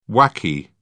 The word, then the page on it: whacky: British English